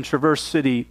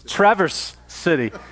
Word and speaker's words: Traverse City: Philip Yancey, in his book, What's So Amazing About Grace, tells a story about a young girl who grew up in Traverse City, Michigan. – Traverse City. – Wow. I've been saying that. Traverse City. – Now you know.